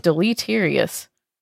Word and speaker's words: deleterious: Deleterious. I need pronunciation guide on that word because I definitely botched it. – Deleterious. – Deleterious. Thank you.